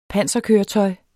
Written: panserkøretøj

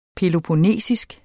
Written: peloponnesisk

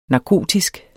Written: narkotisk

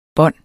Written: bånd

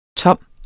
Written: tom

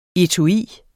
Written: etui

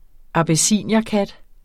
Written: abyssinierkat

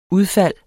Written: udfald

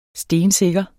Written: stensikker

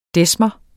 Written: desmer

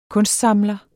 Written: kunstsamler

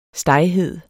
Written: steghed

